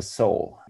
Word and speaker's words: Soel: And there's also a wordplay in it because in the naval jargon, um, yeah, a, a, boat has to have a soul. Pronounce it soul yards because, yeah, that's that's uh, what we think makes the most sense.